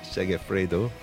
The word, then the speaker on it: Segafredo: Is that how you pronounce Segafredo.